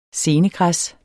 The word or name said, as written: senegræs